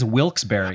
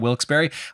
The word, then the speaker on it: Wilkes-Barre: Yeah, it looks like it should, because it's spelled B-A-R-R-E, like a bar class, like the you know, classes that people take. But for some reason, people do call it Wilkes-Barre.